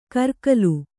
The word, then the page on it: ಕರಗಲು: karagalu